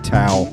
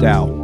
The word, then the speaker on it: Tao: It's not Tao. – Tao, Dow. Spell it, say it right. – Dow. – It's Dow. – It's Dow. – Yeah. – Yeah. Yeah. Dow Day Matt. Sorry, my bad. I wrote it as Tao because that's how you spell it.